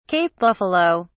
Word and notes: cape buffalo: British English

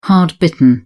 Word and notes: hard-bitten: British English

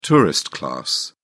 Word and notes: tourist class: British English

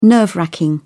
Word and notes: nerve-racking: British English

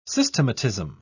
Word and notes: systematism: British English

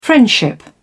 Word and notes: friendship: British English

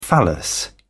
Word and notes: phallus: British English

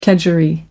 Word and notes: kedgeree: American English

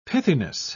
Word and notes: pithiness: British English

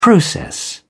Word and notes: process: English; British English

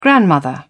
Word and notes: grandmother: British English

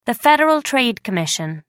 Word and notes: federal trade commission: American English

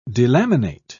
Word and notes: delaminate: British English

